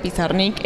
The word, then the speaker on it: Pizarnik: Se supone que debería decirse Pizarnik, debería ser lo correcto.